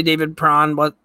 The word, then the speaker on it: David Perron: Apparently David Perron, did I get the name right? – Yeah.